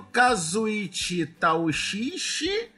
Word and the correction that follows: Kazuichi Tatuishi: Tá errado. – De Shiazae... Ah, foda-se. Kazuichi Tatuishi. E dane-se se eu falei errado de novo. – Dessa vez foi certo.